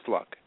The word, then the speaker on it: Fluke: Miss Fluck. It's spelled Fluke. But, of course, when you get fancy.